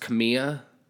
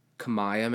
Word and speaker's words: Kamiah: i think it's pronounced camilla K A M I A H. Kamaya, maybe.